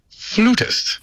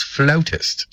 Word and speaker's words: flautist: Proper English pronunciation states that in America, they pronounce it flutist. – Flutist. – But yet, for Canadians and British and Australian, it is preferred to be pronounced as flautist. – Flautist. – Although both are acceptable here in Canada, we choose the word flautist.